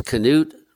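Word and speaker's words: Knute: And correctly pronounced, it's Canute Rockne.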